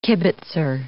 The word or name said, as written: kibbitzer